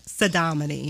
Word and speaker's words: Saudamani: Yeah, Sadomini. – Sadomini. – Yeah, so. – So how do you spell that? Because. – S-A-U-D-A-M-A-N-I.